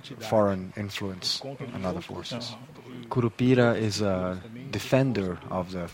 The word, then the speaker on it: Curupira: Tell us about, there's one of the works in the show is Curupira, is that how you say it? – Yes.